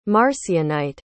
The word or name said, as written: marcionite